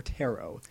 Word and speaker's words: tarot: Is it tarot or tarot? – I think it's tarot. I think it's pronounced tarot.